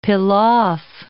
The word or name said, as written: pilaff